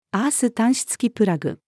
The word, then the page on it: アース端子付きプラグ: female